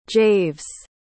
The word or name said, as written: javes